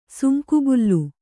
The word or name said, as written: ಸುಂಕುಬುಲ್ಲು